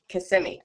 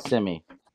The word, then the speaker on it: Kissimmee: Kissimmee. – Yep. Kissimmee.